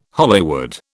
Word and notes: Hollywood: British English

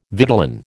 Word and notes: vitellin: American English

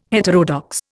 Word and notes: heterodox: Indian

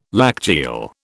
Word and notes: lacteal: British English

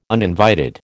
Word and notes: uninvited: American English

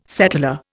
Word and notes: settlor: Indian

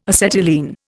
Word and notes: acetylene: Indian

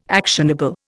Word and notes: actionable: Indian

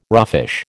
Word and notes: roughish: American English

